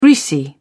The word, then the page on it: greasy: British English